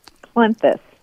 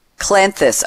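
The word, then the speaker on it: Cleanthes: It's Clenthes. Roman, okay. – Clenthes. – Clenthes, okay. I see C-L-E-A-N, like clean, T-H-E-S.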